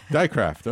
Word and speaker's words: Dijkgraaf: Dijkgraaf, okay.